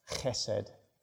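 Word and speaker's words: chesed (חסד): It is a word for love, which in Hebrew is pronounced chesed.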